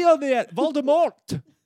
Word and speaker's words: Voldemort: Well, the T is silent. – The T is silent in the French fashion, Voldemort. – Oh, okay. – I believe Voldemort is back.